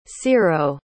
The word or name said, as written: cearo